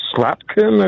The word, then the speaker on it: Slotkin: It occurred to me I made two phone calls, one to Congresswoman Slotkin, I believe is her pronunciation. – Mm-hmm. Alyssa Slotkin, yeah, a former CIA agent, yeah.